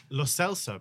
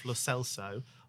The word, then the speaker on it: Lo Celso: Lo Celso, apparently. He has said himself Lo Celso, although we have very learned colleagues, me and Andy, who have said Lo Celso and have been insistent that he is.